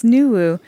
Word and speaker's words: Nuwu: So, can you tell me about Nuwu? – Yes, thank you. Am I pronouncing it correctly? – Yes, Nuwu.